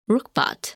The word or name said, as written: Rukbat